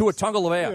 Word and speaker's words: Tua Tagovailoa: Tua Tagovailoa. Yeah. I believe that's how you say his name.